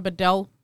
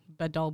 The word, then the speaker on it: Bedel: so uh, my Instagram is Saara Bedell. Um, by the way, my name is spelled S A R A and then Bedell B E D E L.